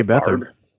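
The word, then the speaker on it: Beathard: Bethard.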